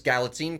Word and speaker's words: Galitzine: With starting with, and I want to make sure I get the name right, Nicholas Galitzine playing He-Man slash Prince Adam.